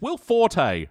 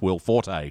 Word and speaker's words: Will Forte: So returning as King Toot is Will Forte, who in the past week, since we've recorded that episode, I now know it's pronounced Will Forte. – Yeah. – And not Will Fort.